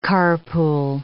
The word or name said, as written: carpool